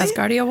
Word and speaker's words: Asgardia: Asgardia 1, did I pronounce that right? – Asgardia 1, that's right.